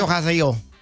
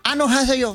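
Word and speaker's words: Año haseyo (안녕하세요): Año haseyo. – Año haseyo.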